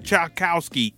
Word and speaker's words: Tchaikovsky: the record that he remixed was a record originally called Dance of the Sugar Plum Fairy by Tchaikovsky, or I'm butchering this original composer name. Is that how that is? – So I think that's how you say it, Tchaikovsky. – Tchaikovsky, yes. Duke Ellington basically remixed it, and he called his version Sugar Rum Cherry.